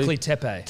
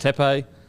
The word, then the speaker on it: Göbekli Tepe: Pyramids, Göbekli Temple, can't even say it properly. – Göbekli Tepe. – Tepe.